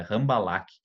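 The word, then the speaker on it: Rambalac: E o Rambalak, é Rambalak, que se escreve com R mesmo, e com C no final.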